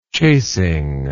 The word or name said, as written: chasing